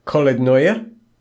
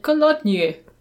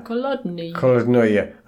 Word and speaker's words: Kolodnoye: The priest is really excited by the fact that he's from England and welcomes Will to this little village called Kolodnoye, which I think is how it's pronounced. – That makes sense. I would have said Kolodnye, but I think Kolodnoye is probably right. – Yeah. – Kolodnoye.